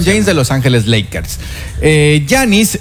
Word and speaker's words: Giannis: Se pronuncia Giannis. – G-I-A-N-N-I-S. – Giannis. El apellido, si no lo pronuncio, porque es... A-N-T-E-T-O-K-O-U-N-N-P-O.